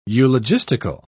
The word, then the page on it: eulogistical: British English